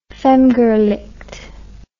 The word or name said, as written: femgericht